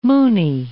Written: Moonie

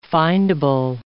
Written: findable

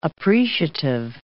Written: appreciative

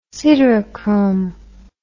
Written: siderochrome

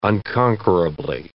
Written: unconquerably

